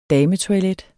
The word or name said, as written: dametoilet